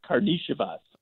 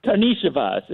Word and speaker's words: Karnisovas: I'm pretty sure it's pronounced Karnisovas. – Okay. I apologize to the family. Say it again. Karnisovas? – Karnisovas. Even though there's not an H in there, the European, I don't know how they do it, but yes, I'm pretty sure it's Karnisovas.